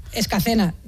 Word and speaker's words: Escacena: Nacida y crecida y vivida en Escalena del Campo, en Huelva. Escacena. Mira que te lo he dicho mal varias veces, por ahí está.